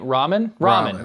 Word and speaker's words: ramen: It's what? Ramon? Ramen? – Ramen. – Why? Who would say that word ramen? That doesn't even make any sense. – So it's R-A-M-E-N, right?